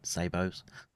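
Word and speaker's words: sabot: I was calling them sabots. Someone corrected me the T is silent. Sabos, apparently.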